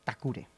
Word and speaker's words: Takure: que se llama Takure, que es como pronuncian los japoneses la palabra tackle, que es placaje en inglés.